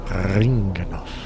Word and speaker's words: Ringanoff: But there's something about it that you have to pronounce slightly. – Roll the R. – There you go. – Ringanoff.